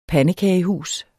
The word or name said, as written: pandekagehus